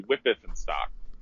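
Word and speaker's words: Wipf and Stock: I always say it wrong. – That's the way I said it forever until I met an editor from that, and they're like, it's actually Wippeth in stock.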